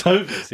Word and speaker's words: Hovis: Hovis, isn't it? – No, it's Hovis, yeah.